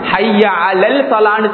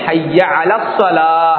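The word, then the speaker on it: ஹய்ய அல ஸலா (حي على الصلاة): ஹய்ய அல ஸலா, ஹய்ய அலல் ஸலான்னு சில இடங்களில் சொல்லப்படுது, ஹய்ய அலல் ஸலா நீங்களும் கேட்டுப்பீங்க, ஹய்ய அலல் ஸலா, ஹய்ய அலல் இல்லை ஹய்ய அல ஸலா,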